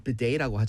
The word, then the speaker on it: bidet: 이렇게 비데 그 영어 발음 bidet라고 하잖아요.